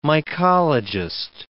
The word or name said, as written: mycologist